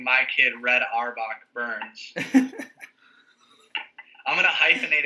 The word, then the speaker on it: Auerbach: Auerbach.